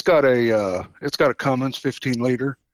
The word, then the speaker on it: Cummins: It's not Cummings. – Did I say Cummings? – No, you said it the right way.